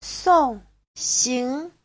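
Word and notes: songxing: song4xing2

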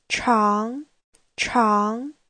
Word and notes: 常常: chang2chang2

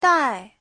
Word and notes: dai: dai4

